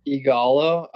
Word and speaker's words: Ighalo: I don't know if I'm saying this right, but Ighalo, is he staying at United? – Ighalo, yeah.